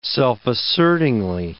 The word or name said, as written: self-assertingly